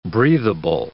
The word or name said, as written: breathable